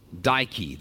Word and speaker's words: Dike: The natives think that this is the work of one of their deities in the Greek pantheon called Dike. Dike. This is the Greek goddess of justice. It's pronounced just like Nike, which is the Greek goddess of victory.